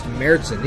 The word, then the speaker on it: Märzen: And usually you'll find on the label the title Oktoberfest, or Märzen, M-A-R-Z-E-N. That's pronounced Märzen.